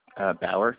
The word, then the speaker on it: Bauer: Dom with a B, D-O-M-E-N-I-C-K. – And what's your last name? – Bauer, B-A-U-E-R.